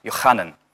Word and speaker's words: Yochanan: In the Hebrew, his name is originally pronounced Yochanan, and I always thought, obviously there's a lot of biblical names like that, like Matthew, Mark, Luke, and John.